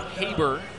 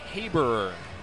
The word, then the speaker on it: Haberer: Pronounced Haber, looks like Haberer. It's H-A-B-E-R-E-R.